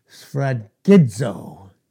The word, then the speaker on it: sphragizo: Sphragizo in the Greek. It's spelled S-P-H-R-A-G-I-Z-O.